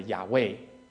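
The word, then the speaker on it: Yahweh: 如果硬是要发音的话呢，接近于我们中文讲的亚伟啊，或许在当中也有人听过亚伟这个名字。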